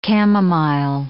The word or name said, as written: camomile